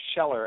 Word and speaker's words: Kjeller: His last name is Scheller, and it's spelled K-J-E-L-L-E-R.